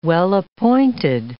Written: well-appointed

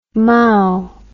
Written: Mao

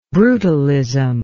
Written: brutalist

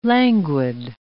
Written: languid

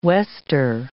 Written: wester

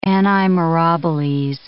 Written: anni mirabiles

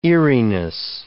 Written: eeriness

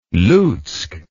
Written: lutsk

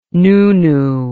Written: nunu

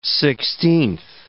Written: sixteenth

